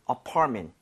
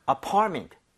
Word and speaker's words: apartment: So 강세를 제대로 주면 MENT가 그렇게 발음이 돼요. 여기 강세 주고 한번 해보면 네? – apartment보다는 apartment. Apartment.